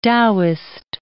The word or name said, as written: Taoist